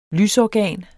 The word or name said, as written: lysorgan